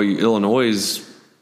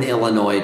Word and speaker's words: Illinois: Well, you enjoy Illinois. I put an S on it. – No, I mean, there is an S on it. It's just not pronounced.